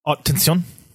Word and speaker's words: Haute Tension: Well, so High Tension, or how do you say it in French? – Haute Tension. – Yeah, there you all go.